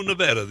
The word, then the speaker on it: Nevada: You're out in Reno, Nevada these days, right, Tom? – That's correct, and you pronounced it right.